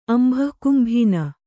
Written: ಅಂಭಃಕುಂಭೀನ

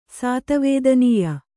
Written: ಸಾತವೇದನೀಯ

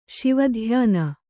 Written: ಶಿವಧ್ಯಾನ

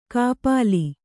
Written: ಖಪಲಿ

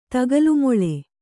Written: ತಗಲುಮೊಳೆ

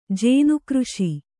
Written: ಜೇನುಕೃಷಿ

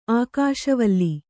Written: ಆಕಾಶವಲೆ

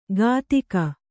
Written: ಕದಿಕ

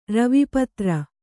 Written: ರವಿಪತ್ರ

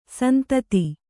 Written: ಸಂತತಿ

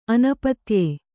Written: ಅನಪತ್ಯೆ

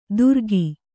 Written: ತುರಕಿ

